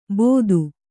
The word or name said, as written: ಬೋದು